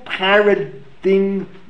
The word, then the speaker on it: paradigm: David Birnbaum and Martin Cohn, New Paradigm Matex. I don't even know what those words mean. Paradigm? How do I pronounce it? P-A-R-A-D-I-G-M. How do you pronounce it? – Paradigm. – Matex. M-A-T-I-X.